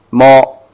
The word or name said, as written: mo